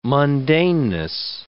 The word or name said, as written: mundaneness